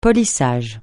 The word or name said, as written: polissage